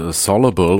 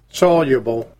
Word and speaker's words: soluble: Ich habe übrigens rausgekriegt, wie man das Wort soluble oder soluble ausspricht. Nämlich ist beides falsch. Eigentlich heißt es soluble. – Soluble. Ja, moderne Technik macht es möglich, dass auch ich das jetzt richtig aussprechen kann.